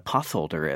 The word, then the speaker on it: potholder: So my wife, Shira, who is a literacy specialist, had a student who asked her what a potholder is. And it's because, you know, the TH can obviously be pronounced th, but it's a compound and has to be broken up in that way.